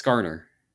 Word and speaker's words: Zskarn: Z-S-K-A-R-N. – Z is always silent. Z is always silent in those names. – Oh, so it's Skarner.